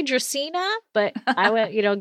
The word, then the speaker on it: Dracaena: And that's so funny that you bring up Game of Thrones because people have such a difficult time saying Dracaena. A lot of them say Dracaena or whatever. And it's Latin, it's Dracaena.